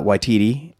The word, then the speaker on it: Waititi: Taika Waititi.